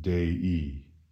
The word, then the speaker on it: Dei: We find it at the end of the word Dei. The I, pronounced E in Latin, is the equivalent of the preposition in English, of.